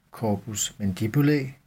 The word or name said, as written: corpus mandibulae